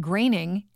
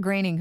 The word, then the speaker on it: Groening: But uh, Matt Groening, and that apparently is the way you say his name, not Groening, Matt Groening,